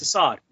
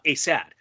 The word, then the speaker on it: Asad: They looked a little bit better in the second half with uh especially with Yamil Asad, it's Assad again. Come on, commentary team. You you have to you have to like find someone that will like help you pronounce that. Sorry that I went off a tangent there, but I just have to get that off my chest. Again, it's Assad, not Asad.